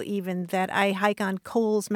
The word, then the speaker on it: Cowles: I would pronounce it Cowles Mountain. Right, Right. I know. And that's that's my problem because properly that mountain is pronounced Coles Mountain. It was named for a guy whose last name is Coles.